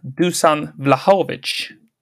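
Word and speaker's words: Dušan Vlahović: Och först upp är eh, Dusan Vlahovic. Ja, bra uttal.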